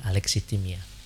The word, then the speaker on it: alexithymia: Tapi mungkin saja dia mengalami alexithymia. Bacanya gitu ya, alexithymia.